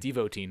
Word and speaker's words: devotee: Pronounced devotee. It's devotee, not devotee.